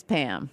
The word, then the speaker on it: Pahaham: P-A-H-A-H-A-M, but it's Pam. – P-A-H-A-H-A-M.